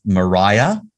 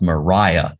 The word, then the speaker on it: Maria: starts with Mariah, yes. who is, you know, I'm usually rubbish with remembering names, but I remember this because she insists that it's pronounced Mariah, not right. Maria.